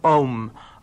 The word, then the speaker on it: Aum: Tous les mantras sont nommés, résumés, si vous voulez, par, au départ, une syllabe, la syllabe « Aum ». Aum, qui s'écrit soit O-M ou bien A-U-M, Aum, donc Aum, qui est...